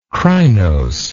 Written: crinosity

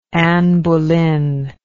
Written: anne boleyn

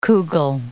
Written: kugel

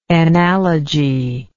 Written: enallage

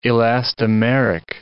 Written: elastomeric

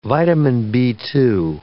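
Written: vitamin B2

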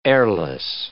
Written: airless

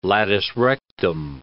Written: latus rectum

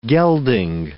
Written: gelding

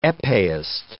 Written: epeeist

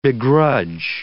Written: begrudge

